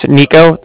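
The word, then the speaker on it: Niko: So it's Nico. That's N-I-K-O. And then I'm calling with the Bank of Cardiff, and that's spelled C-A-R-D-I-F-F, as in Frank, Frank.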